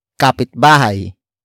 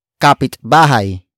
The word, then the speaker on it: kapitbahay: And that is kapitbahay. Kapitbahay. Kapitbahay. Kapitbahay is spelled as K-A-P-I-T-B-A-H-A-Y.